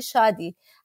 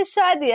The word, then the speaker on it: Shadi: I say Shadi. And first they look at me and say, what? I say Shadi.